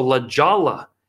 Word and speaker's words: La Jolla: After Provo, I go to Colorado Springs, San Diego, La Jolla is how it's pronounced, very rich area.